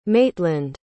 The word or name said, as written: maytland